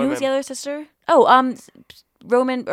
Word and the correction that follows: Ronan: or why can't I say her name? I have no idea how to say it. – Ronan? – Sarcy Ronan.